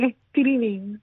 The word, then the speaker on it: streaming: No, la G no se pronuncia. Streaming. Así mismo en la N se acaba. Streaming.